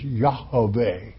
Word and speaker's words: Yahweh: The correct pronunciation is Yahweh.